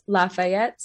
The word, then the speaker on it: Lafayette: So the exact wording of the question is why do Middle Tennesseans pronounce Lafayette so differently than the rest of the world? Um, mm-hmm. – That's a bit of a hyperbole. there are other places in the South that pronounce uh, it Fayette rather than Fayette,